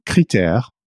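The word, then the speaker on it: Crit'Air: So critère is, so it's a pun. It also means criteria when you pronounce it like that.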